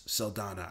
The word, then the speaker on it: Saldana: Yeah, Gamora and Zoe Saldana's Saldana. I hope I'm saying her name right. – Saldana, yeah.